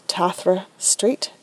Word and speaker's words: Tathra Street: My name is spelled Tathra Street, TathraStreet.